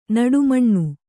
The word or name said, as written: ನಡುಮಣ್ಣು